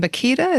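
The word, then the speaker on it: Bakhita: Josephine Bakita, is that how you say her name? – Yes.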